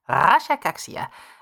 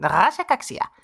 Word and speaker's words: Rasha Kaxia: Like Alexander's Rasha Kaxi. No, it's like Rasha Kaxia.